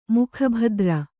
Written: ಮುಖಭದ್ರ

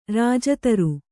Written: ರಾಜಧರ

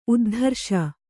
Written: ಉದ್ಧಾರಿಸು

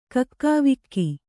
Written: ಕಕ್ಕಾವಿಕ್ಕಿ

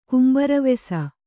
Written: ಕುಂಬಾರವೆಸ

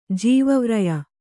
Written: ಜೀವವ್ರಯ

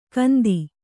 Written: ಗಂತಿ

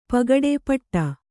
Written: ಪುಟ್ಟಿ

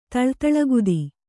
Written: ತಳ್ತಳಗುದಿ

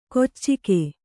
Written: ಕೊಚ್ಚಿಕೆ

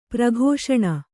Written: ಪ್ರಕಾಶನ

